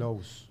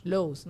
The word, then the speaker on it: Lowe's: Aquí me dejaron un hotel en Atlanta, un Lowe's. Un ¿Cómo se llama? Loeb's. – Lowe's. Lowe's.